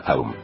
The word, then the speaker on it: Aum: Maestro, ¿cómo se pronuncia el mantra Aum? – Se pronuncia Aum.